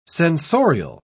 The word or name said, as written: sensorial